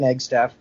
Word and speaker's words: Eggstaff: Yes, it's Johnathan Eggstaff. It's spelled exactly how it sounds.